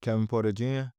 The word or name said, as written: kevin PORTER JR